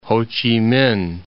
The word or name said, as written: Ho Chi Minh City